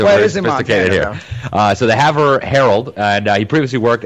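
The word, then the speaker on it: Havre: He's actually the former editor-in-chief of the – how do you say that town, Paul? – Haver. – Haver. Sorry, I don't come from small town Montana. But the Haver – it's French.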